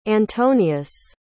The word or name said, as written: antonius